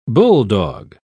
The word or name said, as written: bulldog